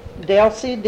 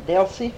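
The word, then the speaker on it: Delsie: Delcy, D-E-L-S-I-E, F-U-Q-U-A, Fuqua, Delcy Fuqua.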